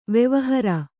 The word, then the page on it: ವೆವಹಾರ: kannada; vevahāra